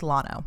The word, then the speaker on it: Llano: yes, Llano is Spanish for plains and the double L is pronounced as a Y. However, this is from the fucking website. The common pronunciation of Llano by the locals is Llano.